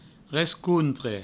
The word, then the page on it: rescontre: Provençal